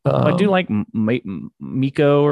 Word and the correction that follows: Mika: Yeah, it's Mika because it rhymes with Pikachu.